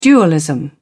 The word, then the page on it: dualism: British English